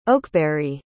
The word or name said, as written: Oakberry